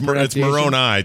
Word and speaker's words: Moroni: It's Moroni technically, but it's just funny to hear other people say it in a different way because there's like a Moroni.